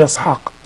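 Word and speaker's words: يسحاق (יצחק): تجد عند العبريين يقول يسحاق.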